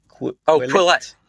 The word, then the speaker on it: Quillette: Quillette.